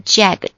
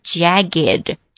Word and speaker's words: jagged: 好，我们把这个单词变形啊，给它加一个 G 再加 ED, 也就是双写 G 加 ED 啊，它的读音并不是 jagged, 而是 jagged, jagged, J-A-G-G-E-D, 哇，我差点读成了 J-A-G-G-E-D 啊 J-A-G-G-E-D, 有的时候脑子它会突然大错线。